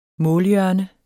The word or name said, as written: målhjørne